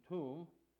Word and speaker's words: Thummim: "And you shall put in the breastplate of judgment the Ur and the Tum," and I'm sorry, I said earlier the way it's written here is Urim and Thummim, but again it's pronounced Ur and Tum in the Hebrew.